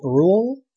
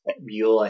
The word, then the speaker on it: Brühl: He's played by Daniel Brühl, I think is how you say his name. There's umlauts. – Brühl, I think it is.